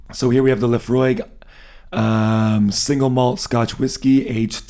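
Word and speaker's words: Laphroaig: We have a Lefroy. Is that how you say it? – Lefroy, I believe.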